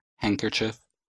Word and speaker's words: handkerchief: Не читается в сочетаниях and в составных словах. Например, sandwich, grandmother, grandchild, Wednesday, handsome, handbag, handkerchief.